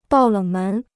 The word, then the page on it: 爆冷门: bào lěng mén